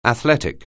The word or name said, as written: athletic